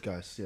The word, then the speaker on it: Geass: Is Code Geass or Geese? Geass. I think it's Geass. – Code Geass, yeah.